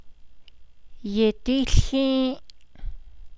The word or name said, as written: yidííłhį́į́’